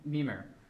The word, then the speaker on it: Mimir: And that's one of my things, one of my favorites, which is Mimir. Did I say that right? – Mimir, thank you. I was like, Mimir doesn't sound right.